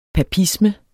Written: papisme

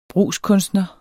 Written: brugskunstner

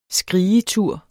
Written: skrigetur

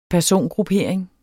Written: persongruppering

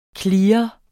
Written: CLEARENDE

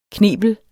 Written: knebel

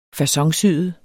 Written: faconsyet

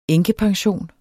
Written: enkepension

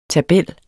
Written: tabel

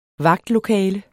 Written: vagtlokale

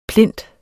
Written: plint

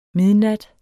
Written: midnat

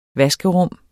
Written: vaskerum

